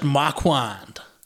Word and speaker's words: Marquand: Directed af Richard Marquand. Ja. Yeah. Sådan tror jeg, at hans navn udtales. Du har helt sikkert ret.